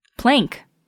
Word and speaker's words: Planck: of Planck plank. – That's pronounced. – that's almost always pronounced. I have always heard that pronounced as plank.